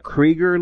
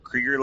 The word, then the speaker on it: Krieger: Krieger like Seeger. – All right. Rob Krieger like Seeger.